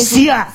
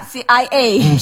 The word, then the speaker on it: Sia: Sia tuh bacanya gimana sih? – Sia. – CIA. – CIA, bukan dong. – SIA. Oh, SIA.